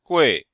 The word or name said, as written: gui4